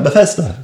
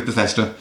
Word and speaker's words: Bethesda: Known as Bethesda? I don't actually know how you pronounce their name. – Bethesda. – Yes. I'm going to go with Bethesda.